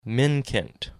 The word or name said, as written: Menkent